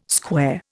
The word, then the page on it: square: Indian